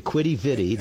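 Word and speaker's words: Quidi Vidi: And when you hear the Newfoundlanders talk and pronounce that, it won't sound anything like what I just said. But it sounds more like Kitty something. But anyway, Quitty Vitty, there's a brewery there and some gift shops and stuff.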